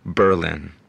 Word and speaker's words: Berlin: It's called Berlin. And yeah, that's how you pronounce it.